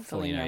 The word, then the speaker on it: Foligno: Nick Foligno? – Foligno. Yeah. – I say his name wrong every time. I say every name wrong. – Like Lou Ferrigno.